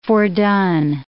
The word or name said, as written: fordone